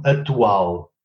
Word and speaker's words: atual: Zum Beispiel das Wort aktuell, ja, wird in Portugal ausgesprochen atual. Ja. Geschrieben wurde es aber aktual. Aber da es ein stummer Konsonant ist, verschwindet das C.